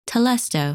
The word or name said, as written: Telesto